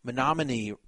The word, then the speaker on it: Menominee: Someone from the 608 area code was impressed that I pronounced the tribe of Menominee correctly. He said, "You pronounced the tribe right, kind of shocked, as most people butcher it."